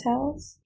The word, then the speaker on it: Towles: Towles? – Amor Towles.